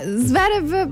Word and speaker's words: Zverev: Se mi correggevo, non è Zverev ma Zverev, allora ha fatto tutta la settimana lo scioglilingua, Zverev, Zverev, sentite come esce adesso come una grondaia, vai benissimo, Zverev, esatto, è come la grondaia Zverev, proprio lo mettiamo sullo stesso piano dell'addominale così come tu lo pronunci e con lo pronunci di Bellingham.